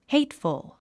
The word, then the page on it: hateful: héitfəl; ヘイトフォ